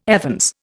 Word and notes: evens: Indian

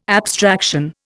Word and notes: abstraction: Indian

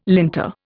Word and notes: linter: Indian

